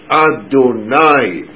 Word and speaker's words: Adonai: It would be pronounced Adonai, my masters, and under the nun there would be a patach.